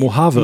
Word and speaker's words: Mojave: E- e- Mojave.